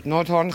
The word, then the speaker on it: Noordhoorn: Woensdag 8 augustus en ik ben in Noordhoorn. Zeg ik het goed? – Ja, je zegt het helemaal goed. Noordhoorn, Groningen.